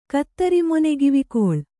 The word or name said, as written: ಕತ್ತರಿಮೊನೆಗಿವಿಕೊಳ್